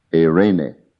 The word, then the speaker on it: eirene: In Greek, it's E-I-R-E-N-E. Pronounced Irene. Very much like the Spanish name of the woman, Irene, Irene.